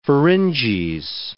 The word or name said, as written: pharynges